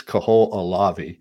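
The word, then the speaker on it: Kahoʻolawe: All right, so we got Hawaii, Maui, Kolahave, Molokai, Lanai, Kauai, and Niihau. – Okay, let's a few. In Hawaiian, we pronounce every single syllable. So it's Kohoʻolawe, it's Lanai, it's Kauai, and Molokai.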